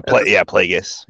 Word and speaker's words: Plagueis: Yeah, Plagueis.